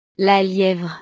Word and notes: la lievre: French

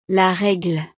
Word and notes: la regle: frances

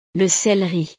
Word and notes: le celeri: frances

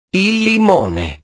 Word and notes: il limone: italiano

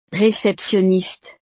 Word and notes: receptionniste: frances

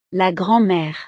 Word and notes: la grand mere: French

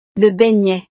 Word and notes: le beignet: frances

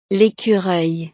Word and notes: l'ecureuil: frances

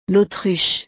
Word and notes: l'autruche: frances

